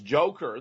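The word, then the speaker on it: Dzhokhar: In fact, I'm in the midst of filing formal charges against Judy Clark, who was Zoker, I'm told it's actually pronounced Joker, the younger brother, for, you know, throwing away his defense in her opening statement saying he did it.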